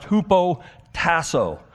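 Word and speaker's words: hupotasso (ὑποτάσσω): The word is hupotasso. It's a great word. And I get that there's a Y in there, but it's pronounced hupotasso.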